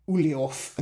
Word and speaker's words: Ulyaoth: Either there, there's Chattur'gha, Ulyaoth, and Xel'lotath.